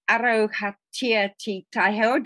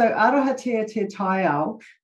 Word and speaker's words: Arohatia te Taiao: And Arohatia Te Taiho, did I pronounce that right? – Pretty close, yeah. – Pretty close.